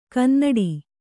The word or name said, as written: ಕನ್ನುಡಿ